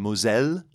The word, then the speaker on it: Moselle: And it was like, you know, these are people that are on the Moselle, which mm-hmm. is how you say it in French.